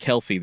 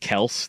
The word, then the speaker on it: Kelce: He actually said it's not Kelce. Their family name is pronounced Kels.